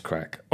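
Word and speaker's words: craic: but I do it for the Craig. I know it's pronounced crack.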